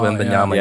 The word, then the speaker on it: Wembanyama: How do you pronounce this? Wembenyama. – Victor oh, Wembanyama.